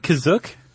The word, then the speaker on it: Kashyyyk: Is that how you say it, Kizook? – That's how it's pronounced in the episode, the holiday special, but it's also pronounced different ways other times. – I would have pronounced it Kashyyyk.